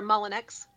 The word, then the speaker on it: Mullinex: So for Don Tedrow, Lucas Dargo, and Shelley, can you say your last name? – Sure, Mullinex. – Mullinex, okay. I just didn't. I wasn't sure. I knew how I would say it. – It's not French.